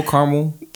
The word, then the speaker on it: caramel: Is it caramel, caramel? – Caramel. I said caramel. – Caramel. Three syllables? – Mm-hmm. – Okay. I would say caramel, which I guess is technically three.